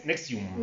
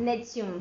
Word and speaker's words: NXIVM: Eh, es la empresa o secta se, se llama NXIVM, o ellos lo, pronunciaban como Nexium. Nexium.